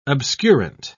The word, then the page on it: obscurant: British English